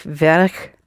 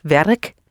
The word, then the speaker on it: werk: Ik werk niet. – With a real K at the end. – Ik werk niet.